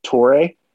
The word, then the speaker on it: Toure: You know, I think you got, um, is it Tor- is it pronounced Torre? Mm-hmm. You're correct.